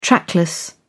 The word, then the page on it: trackless: British English